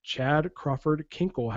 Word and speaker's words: Chad Crawford Kinkle: And of course, I'm talking about Chad Crawford Kinkle.